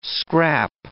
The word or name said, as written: scrap